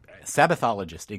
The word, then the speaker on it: Sabbathologist: You are a self-professed Sabbathologist, if I said that right. – Sabbathologist, exactly.